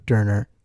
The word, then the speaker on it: Doerner: I did say that right. right? You're right on. I, I even asked you yeah, before the podcast. Right. How do I say it? Look at me like I'm going to say this right. Yeah, it's Doerner. yeah.